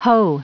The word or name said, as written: hoe